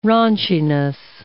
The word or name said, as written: raunchiness